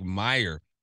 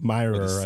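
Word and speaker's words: Mirer: He needs 3 touchdowns to pass Rick Meyer Am I saying that right? – Myerer, I think.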